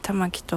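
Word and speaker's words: 玉置: 玉木っていう、まあ、玉を置くと書いて玉木と読むんですけれども、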